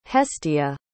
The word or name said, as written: hestia